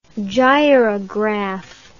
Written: gyrograph

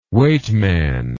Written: weightman

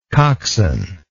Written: coxswainship

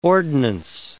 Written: ordnance

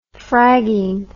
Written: fragging

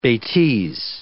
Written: betise

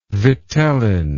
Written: vitellin

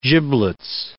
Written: giblets

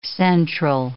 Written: central

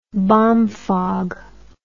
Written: bomfogger

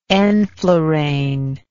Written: enflurane

